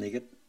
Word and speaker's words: EGOT: So she is now one of the 17 EGOTs. – So she narrated her memoir and won a Grammy for that. – That's so exciting. – She became, as you said. – What's an EGOT? – An EGOT. – EGOT. – EGOT. – Like spell it. – E-G-O-T.